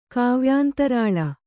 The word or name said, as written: ಕಾವ್ಯಾಂತರಾಳ